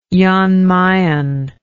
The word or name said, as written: jan mayen